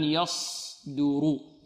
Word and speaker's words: يصدر: البعض ربما لا يضم شفتيه فيظن أنها سين، يقول يص، ربما نقول له إذا أردنا أن ننطقها سين فنقول يصدر، هذه سين، واضح؟ إذن يصدر، دو والدال مرقة، يصدر رو.